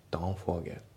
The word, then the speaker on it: Don't forget: で発音ですけど Don't の T が抜けますね。 Don't forget、ね、あと Forget の T も抜けますね。